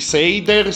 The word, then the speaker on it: Sader: Penso si pronunci Seider, si scrive Sader.